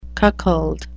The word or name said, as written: cuckold